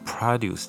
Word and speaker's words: produce: produce 大家可能想说这是产生动词的意思，那但是 produce 它当名词的时候是念作 produce。那么 produce 其实这个字也蛮常用的，特别是你到超市去买菜、买一些吃的东西的时候，你会看到它写 produce，通常指的是农产品哦。